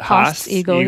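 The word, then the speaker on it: Haast's eagle: Real quick, though, just so that everybody knows, how do you pronounce it? – Haas eagle.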